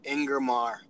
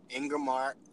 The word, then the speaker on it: Ingermar: It's Ingermar. Ingermar Nixon.